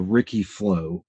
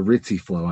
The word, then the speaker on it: Ricci: However, from the outside in, looking in, it appears that there's this thing that's called the Ricci flow, or the Ricci flow. I've heard it pronounced both ways.